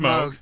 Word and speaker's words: Moog: Moog is the proper pronunciation, but when you see it written down, it's Moog.